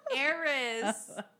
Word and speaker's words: heiress: So, his grandfather married him off to what, the Harris? – Heiress. – Okay, let me try again. – The H is silent.